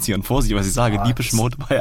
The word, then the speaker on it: Depeche Mode: Guck mal, du sprichst auch richtig aus, ne? Ich schreibe Depeche Mode, Depeche Mode, Depeche Mode. – Habe ich richtig gesagt, oder? – Du hast richtig gesagt.